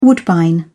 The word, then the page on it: woodbine: British English